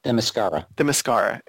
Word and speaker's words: Themyscira: We got a little bit of intel as far as Coriana, which it made it seem a little, and I don't know how to pronounce this place, Themyscira? Is that how you pronounce it? From Wonder Woman? – Themyscira.